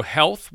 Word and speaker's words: HLTH: There's also Health, which is spelled H-L-T-H. It's left off all the vowels, and it's also being held in Las Vegas.